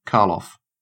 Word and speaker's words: Karloff: Full name, Carl Loff, pronounced Karloff.